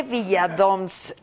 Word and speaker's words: Viladoms: Jorge Villadoms es un cuate. Villadoms, eso dije. Villadoms. Ah, Villadoms se pronuncia Villa Doms, ok.